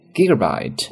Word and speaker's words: gigabyte: 那普通版的手机呢 will now include 32，注意 GB 怎么读呢，它是英文单词 gigabyte 的一个缩写，那么就是 include 32 gigabyte of storage， 32G 的一个内存。